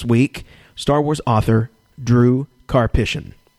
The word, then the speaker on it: Karpyshyn: And we're joined in the cantina this week with Star Wars author Drew Karpyshyn.